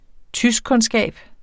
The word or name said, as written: tyskkundskab